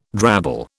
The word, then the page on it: drabble: British English